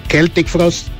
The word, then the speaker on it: Celtic Frost: Si ustedes ya saben qué calibre se manejaba esta banda suiza, Celtic Frost, porque es el nombre correcto de la pronunciación de esta banda, un dato de color ahí.